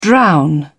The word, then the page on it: drown: British English